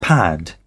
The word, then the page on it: pad: British English